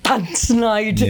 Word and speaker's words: Tanzanite: Tanzanite. – Yeah. Oh, which, you pronounce it correctly.